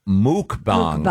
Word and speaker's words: mukbang: No, the word, and it's pronounced mukbang. – Mukbangs. – Mukbang. – Mukbang. – Yeah. It's actually pronounced muk, yeah. – Is it muk? – Mukbangs. – Yep. Oh, I thought it was mukbang. – The pronouncer here is M-O-O-K.